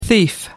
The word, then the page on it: thief: American English